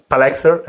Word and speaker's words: PAlexster: In Italian, it was Palexer.